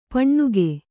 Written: ಪೆಣ್ಣುಗ

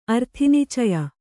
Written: ಅರ್ಥಿನಿಚಯ

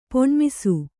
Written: ಪೊಣ್ಮಿಸು

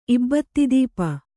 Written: ಇಬ್ಬತ್ತಿದೀಪ

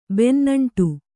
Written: ಬೆನ್ನಂಟು

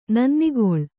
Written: ನನ್ನಿಗೊಳ್

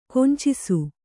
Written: ಕುಂಚಿಸು

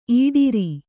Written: ಇಡರು

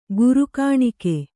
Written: ಗುರುಕಾಣಿಕೆ

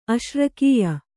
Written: ಅಶ್ರಕೀಯ

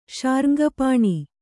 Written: ಶಾರ್ಙ್ಗಪಾಣಿ

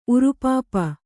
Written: ಉರುಪಾಪ

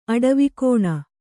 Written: ಅಡವಿಕೋಣ

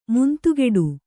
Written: ಮುಂದುಗಡೆ

